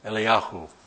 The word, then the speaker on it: Eliyahu: And that man was, it says here, Elias, which is from the Hebrew Elia or Eliyah, which is pronounced Eliyahu. Eliyahu would be, in our vernacular, Elijah.